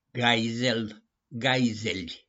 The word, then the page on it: GAIZEL: GAIZELI